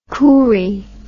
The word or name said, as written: coorie